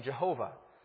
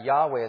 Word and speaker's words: Yahweh: So that's why you have in some versions you talk about the name Jehovah. But we would understand that Yahweh is a closer way to pronounce this name.